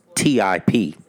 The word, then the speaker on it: Tip: Okay, so they spell it T.I.P. That's how they got it on here. T.I.P. – Mm-hmm. – That's who number 2 is. Tip. T.I.P.